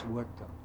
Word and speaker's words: Horta: His last name is spelled H-O-R-T-A. It's pronounced Huerta, Huerta.